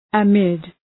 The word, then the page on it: amid: ə’mıd